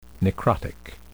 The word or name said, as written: necrotic